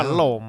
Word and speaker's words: Paloma: Paloma.